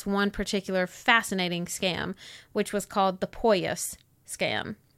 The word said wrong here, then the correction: Poyais: I'm not certain that that's how that would be pronounced. – Poyais.